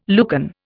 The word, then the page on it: Lucan: Indian